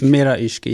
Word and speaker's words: Mera Ishq: How do you pronounce it? Mera Ishq? – Mera Ishq, yeah.